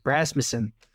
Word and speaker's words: Rasmussen: Rasmussen.